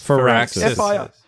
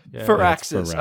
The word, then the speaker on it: Firaxis: Firaxis. – Firaxis.